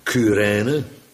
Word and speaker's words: Cyrene: Willekeurig, een Simon van Cyrene, want zo moet je dat uitspreken, heeft men mij vertel, Cyrene vind ik ook altijd zo gek.